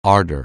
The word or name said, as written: ardour